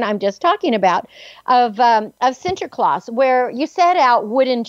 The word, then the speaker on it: Sinterklaas: And I know when I lived in the Netherlands for about 18 months, and Sinterklaas, that's how you say it in Dutch, Sinterklaas, December 6th, was a bigger celebration day than than Christmas Day, and it was something that we really looked forward to.